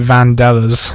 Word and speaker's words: Vandellas: Vandalis or something. – I pronounced it Vandellas, yeah.